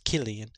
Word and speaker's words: Cillian: It's spelled with a C, but it's pronounced Cillian.